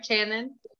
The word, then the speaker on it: Channon: Did I say your name right, Channon? Yes, you did.